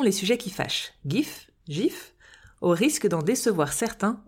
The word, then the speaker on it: GIF: Tout comme le dictionnaire anglais Oxford qui accepte les deux prononciations, mais, et je cite, à tort, c'est un G doux, prononcé GIF.